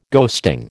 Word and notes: ghosting: American English

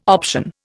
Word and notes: option: Indian

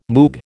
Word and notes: Moog: American English

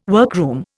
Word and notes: workroom: Indian

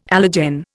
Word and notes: allergen: Indian

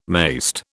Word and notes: mayst: British English

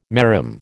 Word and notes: marram: American English

